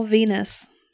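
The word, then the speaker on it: Venis: Night Raw, where he was featured as Val Venus. – I cannot Venus? With him. – Women are from Venus. – It rhymes with penis.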